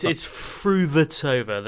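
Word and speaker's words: Fruhvirtová: The new Williams sisters, perhaps, the Fritova, didn't quite roll off. – It's Fruvatova. There's an extra syllable in the middle there.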